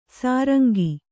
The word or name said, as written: ಸುರಂಗ